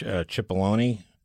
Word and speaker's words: Cipollone: Speaking of which, Cipollone, is that how you pronounce his name? He is the White House counsel, right? – Yes.